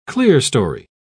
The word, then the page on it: clearstory: British English